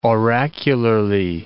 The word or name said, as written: oracularly